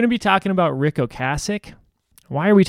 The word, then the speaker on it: Ocasek: Ocasek, is that how you say it? – That's how you say it.